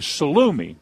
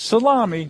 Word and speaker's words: salumi: that's his father's place, and that's where the salumi, not salami, but salumi comes from.